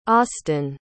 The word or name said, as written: austen